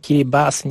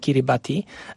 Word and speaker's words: Kiribati: Jeżeli chodzi o ten zwyczaj, oni go wymawiają Pubusi, ponieważ w języku Kiribati litera T i I, jeżeli występują koło siebie, są czytane jako S, stąd Kiribas, nie Kiribati.